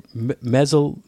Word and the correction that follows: Methuselah: How do you say it? Methuselah.